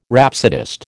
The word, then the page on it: rhapsodist: American English